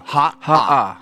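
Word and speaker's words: Ha'a (Haʻa): Like in fact, my daughter has a friend named Ha'a. So it's spelled H-A okina A. So you Ha'a.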